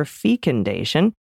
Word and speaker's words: fecundation: Personally, I've always pronounced it with a long E up until now, super fecundation. So I checked with Merriam-Webster and learned that short or long E is acceptable, but I definitely like Dana's way best.